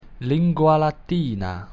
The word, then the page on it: Lingua Latina: Latin